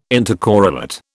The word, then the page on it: intercorrelate: British English